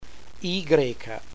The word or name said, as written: Y